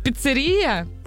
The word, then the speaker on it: піцерія: В цьому іменнику наголос падає на звук «е», запам'ятати можна за допомогою мнемонічного віршика. Ця піцерія називається імперія.